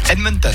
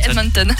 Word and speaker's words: Edmonton: L'équipe de Floride remporte la Coupe Stanley au détriment des Edmonton Oilers, ça se dit comme ça ? – Edmonton. – Ah oui, Edmonton.